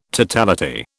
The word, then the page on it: totality: British English